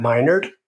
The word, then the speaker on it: Minard: My name is Dr. Matt Minard, pronounced by nerd, rightfully so, and I am honored to be your host.